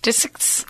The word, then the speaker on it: Disick: Is that how you say his name? – I don't know. Dixick? I don't know. Disick's? – Disick. – Scott Disick.